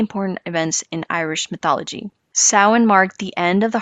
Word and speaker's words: Samhain: So for the longest, I was saying salmon. and then I saw Outlander and I was like, oh, that's how you pronounce it. Okay, got it. So Samhain is a pagan festival originating from an ancient Celtic spiritual tradition.